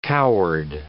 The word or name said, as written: coward